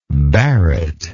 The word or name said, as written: barrett